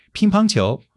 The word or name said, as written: ping1 pang1 qiu2